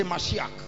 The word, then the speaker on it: Mashiach: I would say Mashiach. Which is like a Messiah. The English will say Messiah. Okay? Mashiach. When you meet a Jew, he will pronounce it well for you.